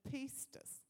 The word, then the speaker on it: pistis: I was looking up the Greek words for faith, and in one of the readings I read, it said that there's the Greek word pistis, which is spelt P-I-S-T-I-S, or pistis.